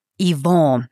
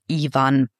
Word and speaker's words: Yvon: Und du hast seinen Namen schon französisch ausgesprochen. Yvon, nicht Ivan.